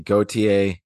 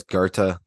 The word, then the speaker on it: Goethe: And yes, um, I've heard people pronounce it Gautier, Gaete. Um, it seems like you know, and and in the episode about him, um, I pronounced it Gautier. The closest you could get is Goethe, like that. That's how the Germans say it.